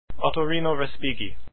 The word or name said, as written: respighi